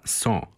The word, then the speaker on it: cent: Tako se francuski sto piše cent, premda se izgovara son, a nekoć se izgovaralo cent, kako se i pisalo, od latinskoga centum.